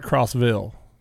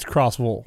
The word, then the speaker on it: Crossville: Now, you know, if you keep going down there, it's not Crossville. It's Crossville. – Crossville. Yeah, I know. – You know, they don't pronounce the Villes like we do up here.